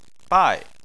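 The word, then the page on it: bai: tone 4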